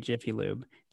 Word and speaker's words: Jiffy Lube: So now, you know, fast forward 20 years and you're at Team Car Care DBA Jiffy Lube.